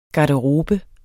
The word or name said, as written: garderobe